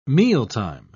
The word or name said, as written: mealtime